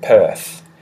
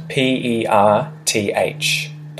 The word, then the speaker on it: Perth: And the capital city of Western Australia is right at the bottom west south corner, and it's called Perth. And it's spelt P E R T H, Perth. Perth. Perth. Perth. So we really say that TH at the end, Perth, but the R isn't really pronounced.